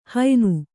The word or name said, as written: ಹಯ್ನ